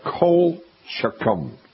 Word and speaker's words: colchicum: It's called colchicum. And I'll spell it for you. C-O-L-C-H-I-C-U-M. Colchicum.